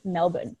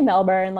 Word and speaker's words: Melbourne: Also, as like a sidebar, I just feel like as an American, you sound like such an asshole pronouncing Melbourne, you know? – Melbourne, yeah. – It's like, I know. Just let me say Melbourne. Like, just let me pronounce the R because I feel super pretentious otherwise.